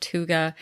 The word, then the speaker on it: hygge: It is not spelled the way it sounds, but it is pronounced hygge.